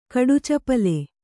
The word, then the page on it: ಕಡುಚಪಲ: Kannada